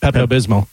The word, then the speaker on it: Pepto-Bismol: There is an L on the end. – Oh, there is. – Pronounce the L? I always pronounce it Pepto-Bismol.